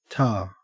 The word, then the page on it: ta: Australian English